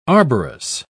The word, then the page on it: arborous: British English